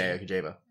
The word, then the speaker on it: Kojima: So, it's a game coming out by, and please forgive me for my terrible pronunciation. – Hideo Kojima. – There you go. Much better than my attempt.